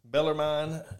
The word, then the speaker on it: Bellarmine: Bellarmine. If that's how you pronounce this, it's – Bellarmine? – Bellarmine. Yeah, Bellarmine.